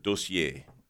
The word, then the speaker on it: dossier: It's D-O-S-S-I-E-R. It's a French word, dossier.